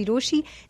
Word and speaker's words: Rochie: मेरा नाम है मिर्ची रोशी, नाम के स्पेलिंग है ROCHIE।